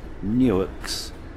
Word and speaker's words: Newark: We then cross the Maryland-Delaware state line, and we travel through one of the U.S. Newarks, pronounced Newark, founded in 1694.